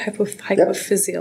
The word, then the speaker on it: hypophyseal: His laboratory focuses on physiological mechanisms of neurosecretion in the hypothalamo-neurohypophyseal system. Am I saying that right? Hypophyseal yep. system. That's how you say it.